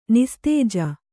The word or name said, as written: ನಿಸ್ತೇಜ